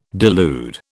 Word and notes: delude: British English

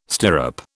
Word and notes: stirrup: British English

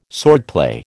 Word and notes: swordplay: American English

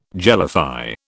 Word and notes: jellify: British English

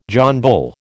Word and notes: John Bull: American English

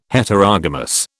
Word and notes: Heterogamous: British English

